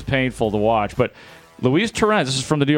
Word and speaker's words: Torrens: is that how you say it? Torrens? – Yeah.